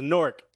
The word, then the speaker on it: Newark: It's pronounced Newark, N-O-R-K.